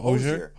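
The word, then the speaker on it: Hozier: Oh, Hosier.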